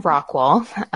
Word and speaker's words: Rockwall: Yeah, I wanted you to pronounce the name because we have in North Carolina, there's a Rockwall And I wasn't sure whether it was Rockwall or Rockwell.